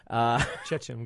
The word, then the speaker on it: Chechen: Gandolfini plays his cousin who used to own the place, but now it's run by Chechen businessmen. – Chechen, I'm glad you got that right.